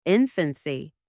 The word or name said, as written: infancy